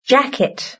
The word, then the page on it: jacket: British English